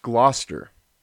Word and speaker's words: Gloucester: And yes, even though it looks like Gloucester, it's pronounced Gloucester.